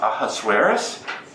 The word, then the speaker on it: Ahasuerus: It's pronounced Ahasuerus.